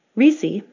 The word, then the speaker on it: Rissi: Risi, my last name, spelled R-I-S-S-I,